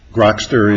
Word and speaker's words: Grokster: Is Grokster the correct pronunciation, by the way? – Grokster is, I believe, the correct.